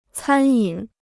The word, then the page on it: 餐饮: cān yǐn